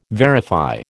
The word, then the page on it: verify: American English